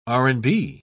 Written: r&b

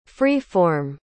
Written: free-form

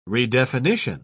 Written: redefinition

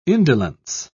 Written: indolence

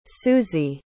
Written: susie

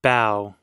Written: bow

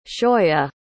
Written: shoya

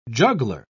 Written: juggler

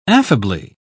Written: affably

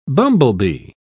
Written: bumblebee

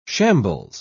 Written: shambles

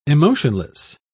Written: emotionless

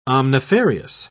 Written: omnifarious

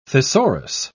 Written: thesaurus

